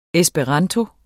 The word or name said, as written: ESPERANTO